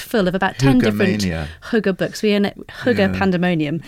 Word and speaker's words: hygge: It's the Danish art of hygge, if I'm pronouncing that right. – Hygge.